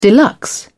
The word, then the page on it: deluxe: British English